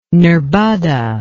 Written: nerbudda

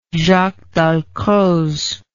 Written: Jaques Dalcroze

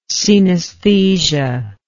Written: cenesthetic

